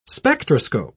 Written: spectroscope

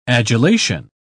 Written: adulation

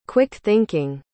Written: quick-thinking

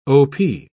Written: op

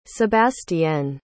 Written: sebastienne

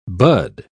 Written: bud

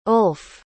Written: ulf

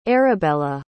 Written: Arabela

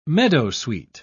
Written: meadowsweet

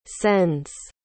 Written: sends

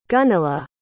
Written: gunilla

